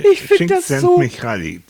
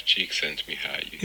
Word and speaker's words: Csikszentmihalyi: Csikszentmihalyi, das habe ich geübt.